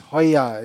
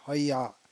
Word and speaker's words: Hoya: This is pronounced Hoya. If you look at the spelling, it doesn't look like that, but it's pronounced Hoya.